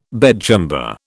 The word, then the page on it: bedchamber: British English